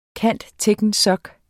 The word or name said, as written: cand.techn.soc.